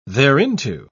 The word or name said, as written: thereinto